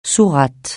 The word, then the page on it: sourate: francais